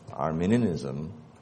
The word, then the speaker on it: Arminianism: It's spelled A-R-M-I-N-I-A-N-I-S-M. If you type A-R-M-E, you'll get a country. Okay? And it's pronounced differently. I know English is very hard to pronounce. Chinese is a bit easier sometimes. But that was Armenia. This is Arminia.